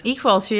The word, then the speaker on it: Equalture: How did I say it correctly? Actually, it's Equalture, right? – Equalture.